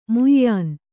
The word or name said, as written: ಮ್ಯಾನಾ